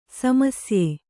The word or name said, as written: ಸಮಸ್ಯೆ